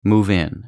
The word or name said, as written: move in